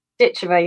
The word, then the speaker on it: Ditcheva: And it's Ditcheva, right? Am I pronouncing your last name correctly? – Ditcheva, yeah.